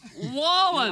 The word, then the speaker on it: Wallen: Is it Wallen? You've said it wrong so many times, now I'm starting to doubt myself. I think it's Wallen.